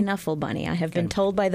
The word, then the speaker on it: Knuffle Bunny: And then the following day, we will have a performance of The Knuffle Bunny, which is a much beloved children's book.